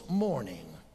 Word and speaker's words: mourning: One signals morning, M O U R N I N G. The other signals morning, M O R N I N G. They are pronounced exactly alike, but the spelling is different.